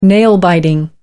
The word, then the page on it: nail-biting: American English